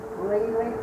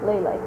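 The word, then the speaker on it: Lilla: Lily.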